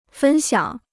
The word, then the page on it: 分享: fēn xiǎng